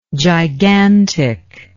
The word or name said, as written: giganticness